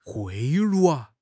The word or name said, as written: 贿赂